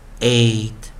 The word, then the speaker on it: eight: way way way e i g h t 我们念 eight eight eight 首音 f r fr fr fr way freight freight freight wait wait wait 这个就是让同学们有一个概念了，在有时候我们在 e i 的时候，就 variant vowel 啊破音或者我们讲说特例的时候，发出长元音 a，然后的几个发音，那么在最后再来带一遍呢。 weigh, weigh, weigh. Eight, eight, eight.